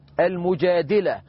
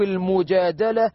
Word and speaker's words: المجادلة: عندنا في القرآن سورة نزلت باسم امرأة مريم، عندنا في القرآن سورة نزلت بسبب شكوى امرأة المجادلة أو المجادلة كما ينطقها البعض.